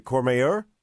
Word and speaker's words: Courmayeur: Is it Courmayeur? – That's right, yes. You take a bus, a shuttle bus, through the Mont Blanc tunnel. So basically, half an hour from Chamonix, you'll arrive in Courmayeur, and there you have another fantastic ski area, essentially intermediate, lots of tree skiing, lots of lovely Italian restaurants on the peak.